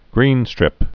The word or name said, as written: greenstrip